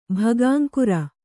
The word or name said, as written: ಭಗಾಂಕುರ